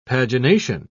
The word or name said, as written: pagination